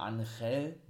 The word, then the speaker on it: Angel: Sie heißen ja nur noch Umberto und Angel oder Angel, ne, so wurde der eigentlich ausgesprochen,